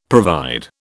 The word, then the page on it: provide: British English